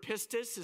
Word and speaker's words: pistis: It's the Greek word pistis is how you pronounce it.